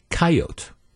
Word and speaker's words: coyote: If you are, for example, from the Dakotas, the word coyote, it's pronounced coyote. That's it. The E is silent.